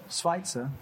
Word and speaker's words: Schweitzer: Schweitzer, thank you.